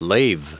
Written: lave

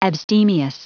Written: abstemious

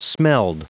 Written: smelled

